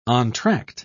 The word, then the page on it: entr'acte: British English